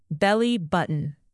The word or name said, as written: belly-button